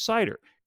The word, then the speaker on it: CIDR: So they then decided to issue what they call a classless or classless internet domain routing, or CIDR, pronounced as CIDR.